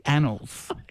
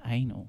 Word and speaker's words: Annals: This is Annals. Gosh, there's a clear pronunciation difference there, girl. You made me say it, and now look what you've done. I tell you what. – Don't you blame me. That's on you. – No. You misinterpreted the pronunciation, which I said is annals.